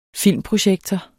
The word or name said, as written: filmprojektor